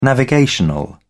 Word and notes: navigational: British English

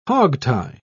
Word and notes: hogtie: British English

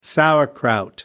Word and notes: sauerkraut: American English

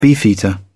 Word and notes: beefeater: British English

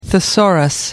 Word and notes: thesaurus: American English

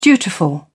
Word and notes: dutiful: British English

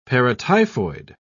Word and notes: paratyphoid: British English

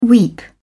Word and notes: weep: British English

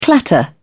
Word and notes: clatter: British English

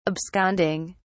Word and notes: absconding: British English

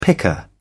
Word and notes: picker: British English

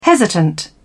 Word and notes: hesitant: British English

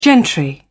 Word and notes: gentry: British English

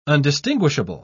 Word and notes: undistinguishable: British English

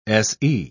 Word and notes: se: British English

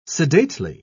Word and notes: sedately: British English

